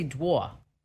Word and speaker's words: drawer: How do you say drawer? How do you say that word? – Drawer. – Okay. See, you're from New Mexico. I'm from New Jersey. We say dwa.